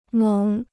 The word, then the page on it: 猛: měng